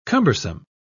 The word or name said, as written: cumbersome